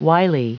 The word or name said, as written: wily